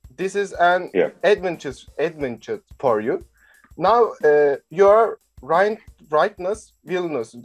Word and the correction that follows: Rytas Vilnius: is I pronounce right? Rightness, Vilnius. – Rytas Vilnius. – I'm not good in language, so Rytas Vilnius, assistant coach.